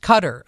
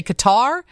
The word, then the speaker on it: Qatar: It is pronounced Cutter. I hate to break that to you, but I was on the radio back in the day when we had people in that country. And I know a lot of people want to say Qatar. I think it's acceptable in this country to say Qatar or Cutter, but I think the correct pronunciation is Cutter.